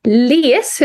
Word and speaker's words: Lehes: Ja, idag har vi med oss Annika och Timo Les. Hur uttalar man ert namn egentligen? Var det ja, rätt så? Yes.